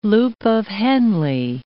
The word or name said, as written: loop of Henle